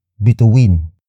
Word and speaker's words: bituin: The next word is bituin. Bituin. Bituin. Bituin is spelled as B-I-T-U-I-N.